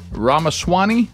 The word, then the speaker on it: Ramaswamy: So we do have, it's Rama. Rama. – Ramaswamy.